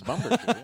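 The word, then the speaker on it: Bumbershoot: Yeah, and then be a bummer shoot. – It sounded like you said bummer shoot. – Hey, for Matt, it is. – Bumber shoot, yeah.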